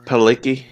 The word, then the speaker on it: Palicki: Adrian Palicki? – Palicki, yeah.